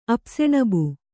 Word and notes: ಅಪ್ಸೆಣಬು: apseṇabu

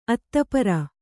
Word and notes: ಅತಃ ಪರ: atah para

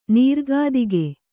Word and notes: ನಿರ್ಗತಿಕ: kannada

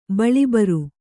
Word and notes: ಬಳಿಬರು: baḷi baru

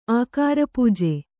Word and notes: ಅಗ್ರಪೂಜೆ: agrapūje

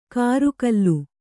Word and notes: ಕರುಗಲ್ಲು: karugallu

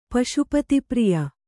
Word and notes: ಪಶುಪತಿಪ್ರಿಯ: Kannada

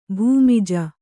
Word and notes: ಭೂಮಿಜ: bhūmija

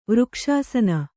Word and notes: ವೃಕ್ಷಾಸನ: vřkṣāsana

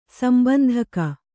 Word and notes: ಸಂಬಂಧಕ: sambandhaka

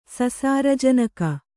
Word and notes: ಸಸಾರಜನಕ: sasāra janaka

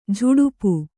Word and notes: ಝಡಪ: jhaḍapa

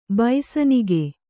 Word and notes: ಬೈಸನಿಗೆ: baisanige; Kannada

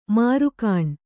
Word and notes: ಮೆರುಗೆಣ್ಣೆ: merugṇṇe

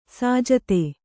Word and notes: ಸಜ್ಜಾತಿ: sajjāti

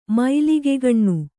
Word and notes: ಮೈಲಿಗೆಗಣ್ಣು: Kannada

